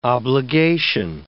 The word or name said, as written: obligation